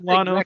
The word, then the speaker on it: Lono: Or is it Lano or Lono? – I call him Lano, but... – Just like Bono. Yeah. Okay, we'll call him Lano. Exactly. Rhymes with Bono.